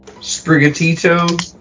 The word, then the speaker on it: Sprigatito: Spigarato, is that how you say its name? – Sprigatito? – Yeah.